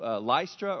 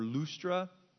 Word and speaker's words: Lystra: But then he gets to Lystra or Lustra, and that's where he picks up Timothy.